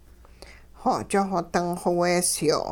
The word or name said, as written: xo'ch xo-ding xoweesyo'